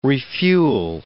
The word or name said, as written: refuel